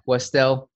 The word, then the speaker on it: Westell: Westell.